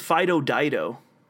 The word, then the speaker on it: Fido Dido: Yeah, but his name was Fido Dido, and I don't know why. – Like Dido, like the singer.